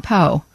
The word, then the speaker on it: Poe: Edgar Allan Poe. – Oh, okay. I just wanted to make sure you can make a long O sound.